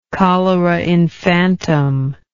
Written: cholera infantum

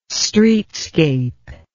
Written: streetscape

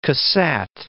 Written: Cassatt

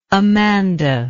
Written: Amanda

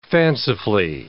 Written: fancifully

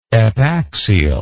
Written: epaxially

